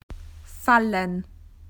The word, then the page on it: to fall: Australian English